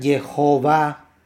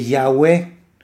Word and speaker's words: Jehová: Entonces, me hace más sentido, por ejemplo, a mí, a un servidor, con base en esta información, pronunciar Jehová que Yahweh. ¿Por qué? Porque Yeho está al principio, Jehová, y Yahweh está como más relacionado a Yahú, como si fuera un sufijo al final del nombre, cuando en realidad se utiliza normalmente al final del nombre.